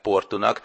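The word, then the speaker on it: Porto: Ezt úgy írják egyébként, hogy Tejo, és Tejo-nak ejtik, Portót meg Portunak, tehát a szóvégi ó helyett út kell ejteni,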